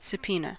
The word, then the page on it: subpoena: American English